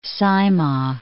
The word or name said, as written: Saimaa